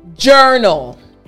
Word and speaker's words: journal: And also realize that this sound is coming from your throat as well. J, J. Like if you're gulping down water. So again, the entire word after me. Journal. Excellent. Last time, journal.